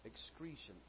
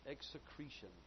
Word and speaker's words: execration: Excretion. Execretion is the proper pronunciation. E-X-E-C-R-A-T-I-O-N, execretion.